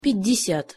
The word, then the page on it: pjat-desjat: Russian